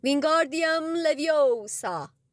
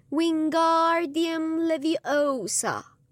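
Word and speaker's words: Wingardium Leviosa: دست‌های درازش را مثل آسیاب بادی تکان می‌داد و فریاد می‌زد: وینگاردیوم لویوسا. هری صدای هرمیونی را شنید که با تشهر به رون گفت: داری اشتباه تلفظ می‌کنی. باید بگی وینگاردیوم لویوسا. گارش رو رسا و بلند تلفظ کن.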